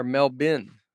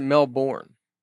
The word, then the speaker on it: Melbourne: Melbourne is pronounced Melbourne or Melbin. Never pronounce it Melbourne. The locals don't like it.